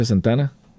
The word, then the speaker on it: Santana: ¿Cómo que se pronuncia Santana? – Tal cual como tú lo hiciste.